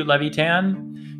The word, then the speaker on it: Levitan: I Googled how to pronounce LEVY TAN and it brought up her Instagram where I found a cute video of her mom and her with her mom repeating, "I love you, Levitan."